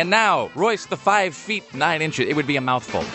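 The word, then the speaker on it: Royce da 5'9: Apparently, that hip-hopper is just referred to as 5'9", or Royce the 5'9". You don't pronounce the feet or inches,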